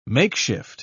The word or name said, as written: makeshift